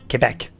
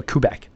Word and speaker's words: Quebec: 然后呢，首先讲一下魁北克、蒙特利尔，不是魁北克啊。我们这毫无疑问，我们法语发音叫做 Quebec 啊啊，英语叫 Quebec，魁北克。